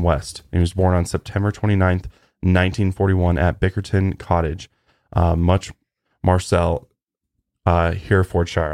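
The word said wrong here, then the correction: Much Marcle: I probably pronounced that wrong. – I think it's Markle. Much Markle. – Much Markle. Okay.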